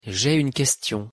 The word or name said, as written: j'ai une question